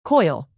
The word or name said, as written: coil